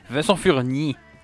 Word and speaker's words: Vincent Furnier: Je, dois le prononcer à, l'anglophone, mais je trouve ça drôle de le dire. – Je trouve ça drôle de le dire à la française. Vincent Furnier.